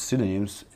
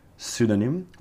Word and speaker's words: pseudonym: Some writers use pseudonyms instead of publishing books under their real name. It's just like a nickname, okay? Remember, pseudonym with a P in the beginning, pseudonym is a silent P, and nickname, very close to each other.